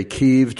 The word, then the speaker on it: Kyiv: and sometimes I say Kiev, just like the reporters are doing on television. Mykola told me that Kiev is the Ukrainian pronunciation, right? – Right, right.